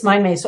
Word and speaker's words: MindMaze: Yep, it's Mind Maze.